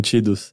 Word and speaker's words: Tidus: tipo, me dá muita raiva que a pronúncia dele é Tidus. Porque o nome dele é... Tidus, tipo, era pra ser Tidus, de Tidal Wave, de Tide. Mas é Tidus a pronúncia oficial.